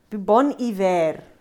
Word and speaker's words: Bon Iver: The name bon iver derives from the French phrase, bon hiver, French pronunciation. Ek kan nie fonetika lees. – Nee, maar daaronder staan hy in lettertjies. – Good winter. Taken from a greeting on the northern exposure, it is pronounced bon iver.